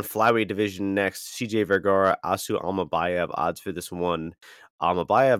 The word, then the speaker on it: Asu Almabayev: Asu Almabayev.